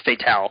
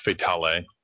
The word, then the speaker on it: Fatale: It's called like Fatale or Fatal. I'm pretty sure it's Fatale. – I think it's Fatale.